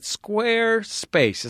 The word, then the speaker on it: Squarespace: We also have another supporter this week, our friends at Squarespace. Is that how it's pronounced? – Yes, you've pronounced it perfectly.